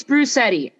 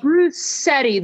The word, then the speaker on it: Bruschetti: I always tell people it rhymes with spaghetti, so that's... – Bruschetti. Yes. Did I say it right? Bruschetti. – Bruschetti. There's no... That C sound isn't in there.